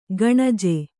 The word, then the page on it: ಕಣಜು: kaṇaju